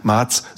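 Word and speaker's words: Mads: Und ich darf mal dazu sagen, Mars, so spricht man übrigens aus, ohne D, Mars.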